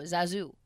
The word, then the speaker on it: Zazoo: How I thought, Z-A-Z-O-O, Zazu.